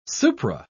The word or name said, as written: supra